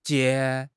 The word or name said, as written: jie2